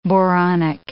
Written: boronic

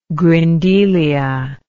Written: grindelia

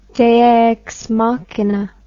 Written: dea ex machina

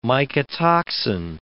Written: mycotoxin

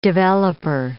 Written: developer

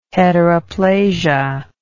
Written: heteroplasia

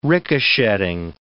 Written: ricochetting

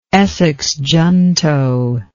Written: Essex junto

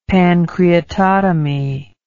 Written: pancreatotomy

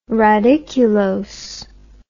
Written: radiculose

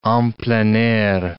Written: en plein air

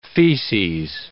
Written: feces

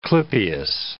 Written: clypeus

